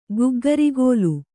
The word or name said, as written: ಗಗ್ಗರಿಗೋಲು